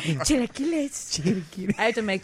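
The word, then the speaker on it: chilaquiles: Chilequiles. Esmeralda, am I still saying that incorrectly? Chilequiles. – No, you're saying that right.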